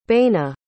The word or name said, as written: baina